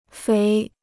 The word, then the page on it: 肥: féi